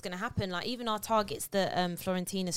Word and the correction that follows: Fiorentina: striker, I can't pronounce his name. – Fiorentina. So Florentina. – Fiorentina. – Fiorentina.